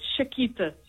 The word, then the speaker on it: Chiquita: So how do you say it? – It's Chiquita. – Oh, Chiquita. – Chiquita. Okay. Yes. Spelt with a C-H, though. – Yes, C-H-I-Q-U-I-T-A.